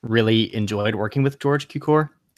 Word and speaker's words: Cukor: Good old friend George Cukor, is that how you say it? – Yeah.